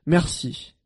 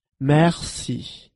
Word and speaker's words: merci: In French, thank you is merci. Merci. Let's break it down by syllable. Merci. Now let's hear it once again. Merci.